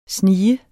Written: sniger

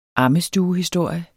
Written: AMMESTUEHISTORIERNE

